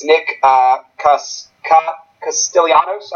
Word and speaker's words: Castellanos: Yeah, he's a guy that could take a big step for them, and they have a couple young guys, and I'm going to butcher his name. That's Nick Castellanos, I believe it is.